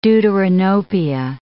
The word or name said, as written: deuteranopia